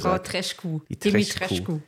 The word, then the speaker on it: Dimitrescu: Ich möchte hier einhaken. Es heißt Lady Dimitrescu.